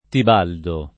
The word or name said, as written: Tibaldo